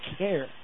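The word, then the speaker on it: Kejr: By the way, you spell his last name K-E-J-R. K-E-J-R, pronounced Kerr. It's all right. Our radio station in Atchison pronounces Kerr K-A-I-R.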